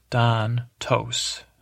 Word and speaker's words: Dantos: I mean, Dantos, that's 2 syllables. Dantos. I don't know which is the hard syllable or they're both double hard, but 2-syllable names are usually good for a lot of stuff.